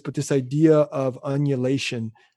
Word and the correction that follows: undulation: You forgot the D, but unulation sounds pretty good too. – Okay, this idea of undulation.